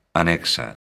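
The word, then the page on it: anexo: galega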